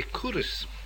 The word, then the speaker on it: Culross: K-I-R-C-U-D-B-R-I-G-H-T. Kirkcudbright. But it's called Kirkcudbright. There's another place I remember too, a place in Fife called Culross. You say Cooris.